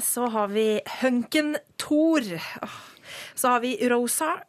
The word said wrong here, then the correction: Rorschach: Jeg klarer aldri å uttale det navnet. – Rorschach.